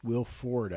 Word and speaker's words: Wilford: I look much better than Wilfred, Will Ford, I believe it is, not Wilfred, Will Ford Brimley, I think.